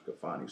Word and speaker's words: Kafani: It was spelled Khalfani, but I kind of took it tight to make it how it sound. – Right. – It's pronounced Kafani, so I just spelled it Kafani, K-A-F-A-N-I.